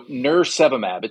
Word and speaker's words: nirsevimab: So nirsevimab, it's almost like nurse and then virus and then monoclonal antibody. So nirsevimab, right? So I think here, maybe there's three important things to say about nirsevimab.